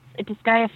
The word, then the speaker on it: Disgaea: Now, you've done, I'm going to, I don't know if I'm pronouncing this right, you let me know, is Disgaea 5? – Oh, yes. It's, Disgaea 5 is, like, a super cool game.